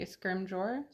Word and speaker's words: Scrimgeour: A soft G. Scrimgeour. – Scrimgeour.